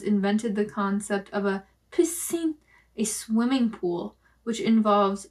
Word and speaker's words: piscine: P-I-S-C-I-N-E. Piscine. – Piscine. – That's how I pronounce the word.